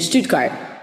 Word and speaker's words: Stuttgart: So, well, in Stuttgart. Did I say that right? Did I say that like a German? – Yes, absolutely. Germany. Perfect. Stuttgart, southern Germany.